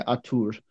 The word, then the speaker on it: Artur: They had um, well, it's spelled Arthur, but I think you say Artur.